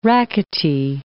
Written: rackety

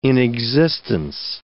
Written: inexistence

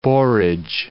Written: borage

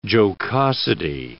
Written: jocosity

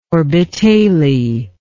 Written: orbitale

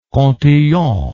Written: Cantillon

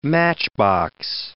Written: matchbox

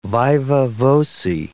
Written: viva voce

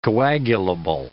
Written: coagulable

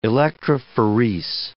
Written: electrophorese

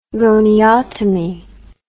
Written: goniotomy

